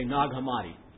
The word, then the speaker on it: Nag Hammadi: I say recently, I mean in my lifetime, have been unearthed at a place in Egypt called Nag Hammadi, N-A-G, new word, H-A-M-M, A-D-I. It's probably pronounced something like Nag Hammadi, but we Americans say Nag Hammadi.